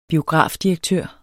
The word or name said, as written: biografdirektør